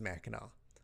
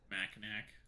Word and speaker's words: Mackinac: So if you look at the map and you look at Saint or not Saint Ignace at Mackinac Island, it is spelt Mackinac, but it's Mackinac. – Mackinac.